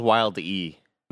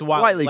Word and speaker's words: Wile E.: I always thought it was Wild E. – No, it's Wile E. Like Coyote. Wiley, but it's Wile.E. Coyote.